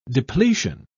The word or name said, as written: depletion